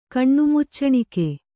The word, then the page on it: ಕಣ್ಣುಮುಚ್ಚಣಿಕೆ: Kannada